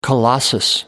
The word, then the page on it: colossus: American English